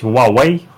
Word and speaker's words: Huawei: И в данном случае пострадал Huawei, или, как правильно говорить, Huawei.